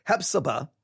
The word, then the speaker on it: Hephzibah: Hephzibah. And that's spelled, if you don't know how that's spelled, H-E-P-H-Z-I-B-A-H. Hephzibah. H-E-P-H-Z-I-B-A-H. The Lord delighteth.